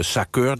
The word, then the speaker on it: SACEUR: Dat is in vaktermen de SACEUR, dat is zeg maar de hoogste NAVO-generaal, althans de commandant die de strijdkrachten van die NAVO aanstuurt.